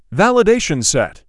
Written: validation set

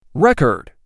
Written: Record

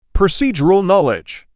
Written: Procedural knowledge

